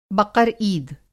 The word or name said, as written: बक़र-ईद